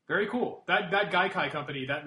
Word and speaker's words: Gaikai: I don't know how to pronounce it, but it's G-A-I-K-I. G-A-I-K-A-I. – I think that's right, yeah.